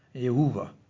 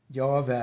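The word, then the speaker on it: Jahve: Men eh, det er veldig usannsynlig at navnet har vært uttalt Adon Jehova. Jave eller Jave.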